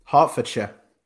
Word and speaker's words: Hertfordshire: Hertfordshire, UK? – Hertfordshire.